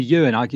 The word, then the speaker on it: Euan: Well, the reason I was calling you Dr. Lawson was your name is spelled E-U-A-N, and I wasn't quite sure how to pronounce Ewan.